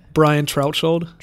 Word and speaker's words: Brian Trautschold: How do you say it? Brian Trautschold. It's like a hard consonant in the middle, but most people add like L's or M's into my last name, so Velasquez, stuff like that.